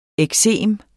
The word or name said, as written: eksem